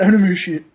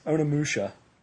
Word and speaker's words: Onimusha: So we got Onimusha. Oh my gosh, I pronounced it wrong after that. – I know. – That's how I used to pronounce it. – I know. – Now it's Onimusha.